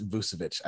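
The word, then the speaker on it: Vučević: They have I don't know how to say his name, but it's Vucevic. – He was with Orlando. He was their double double guy. – Oh my God. Yeah, it is Vucevic. I can never fucking say it either. Nikola Vucevic.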